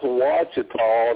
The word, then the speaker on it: Ouachita: Ouachita. – Any lake, any lake in Arkansas is odd to me. Okay. yeah, well, so it's it's, it's pronounced Wachita, and it's spelled Ochita or something, you know.